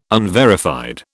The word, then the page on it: unverified: British English